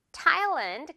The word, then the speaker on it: Thailand: TH라서 뭔가 이거 메롱 발음. 메롱 발음 해야 될것 같은데 그게 아닌 경우입니다. Thai가 아니라 Thailand 그대로 가면 돼요.